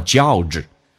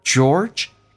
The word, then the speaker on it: George: 那么 George 来到他朋友的家里面，然后呢开始进行敲门。这个单词 George 很多人发音不标准，发的这个味儿很大，叫做 George，还有脚气哈。George 嘴巴收圆，or George